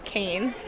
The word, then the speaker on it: Kain: Jenny Kane, J-A-E-N-E-N-N-Y, and then K-A-I-N.